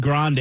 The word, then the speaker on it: Grande: I'm both ways now because Ellen said Grande yesterday, and Ellen is the coup de grace of pronouncing people's names right. But she said Grande in an interview a long time ago.